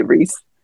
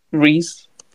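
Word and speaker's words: Rhys: I just say Reese. – Reese, yes. Like Reese's Pieces.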